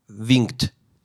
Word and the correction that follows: Winkel: Winkel, que traduce como The Man with the Pink Triangle.